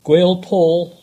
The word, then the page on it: Gwealpaul: English